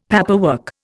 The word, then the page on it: paperwork: Indian